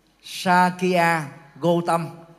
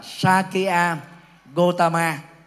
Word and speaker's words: Sakya Gotama: Đức Phật Sakya Gotama. Hay còn đọc là Sakya Gotama.